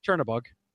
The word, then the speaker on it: Chernobog: Cernobog is the greatest character ever. – Cernobog. Or have you pronounce it, but Chernobog. – Like Chernobog.